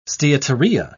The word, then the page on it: steatorrhea: British English